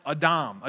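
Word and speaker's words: Adam (אָדָם): And it's interesting that the Hebrew word for humanity is this word that is pronounced Adam.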